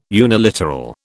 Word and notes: uniliteral: British English

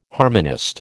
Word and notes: harmonist: American English

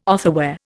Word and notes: otherwhere: Indian

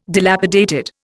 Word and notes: dilapidated: Indian

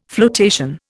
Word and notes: floatation: Indian

